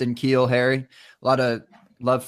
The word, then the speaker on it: N'Keal: A lot of love for, I'm going to pronounce his name wrong, Keneal Harry? – N'Keal. – What is it? – N'Keal. – N'Keal? I switched the N and the K.